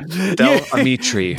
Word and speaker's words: Del Amitri: First of all, you've been mispronouncing the name of this band since day one. – Oh yeah, Del Amitri.